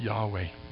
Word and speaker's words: Yahweh: The Hebrew word for Lord is often pronounced Yahweh.